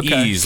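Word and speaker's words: Ys: I always said it's not Wise. No, it's Ease, like Rory.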